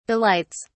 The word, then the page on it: delights: British English